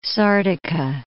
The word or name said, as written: Sardica